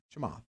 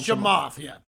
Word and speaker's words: Chamath: Chamath. Most people just call him Chamath.